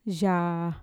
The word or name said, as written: yaa